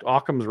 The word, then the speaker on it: Occam: What's that theory that uh, the simplest explanation is is often the the correct Occam's razor? Is that what it's oh, called? I th- yeah, I think that's right. Is that how you say it, Occam? – Yeah, I think that's right.